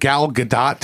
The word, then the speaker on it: Gal Gadot: Gal Gadot. That's the right pronunciation, correct? – That's what I saw. That's what I double checked.